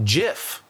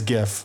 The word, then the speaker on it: GIF: Well, the G in GIF stands for graphic with a G sound. So GIF.